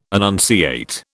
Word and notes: annunciate: British English

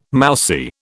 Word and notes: mousy: British English

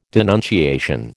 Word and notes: denunciation: American English

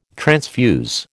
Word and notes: transfuse: American English

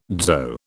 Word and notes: dzho: British English

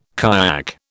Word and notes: kayak: British English

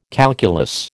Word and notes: calculus: American English

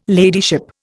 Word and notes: ladyship: Indian

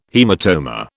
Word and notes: haematoma: British English

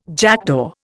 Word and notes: jackdaw: Indian

